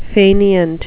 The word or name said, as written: faineant